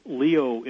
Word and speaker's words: Lyot: We found such an impact crater called the LIOT impact crater. It's spelled Lyot, L-Y-O-T.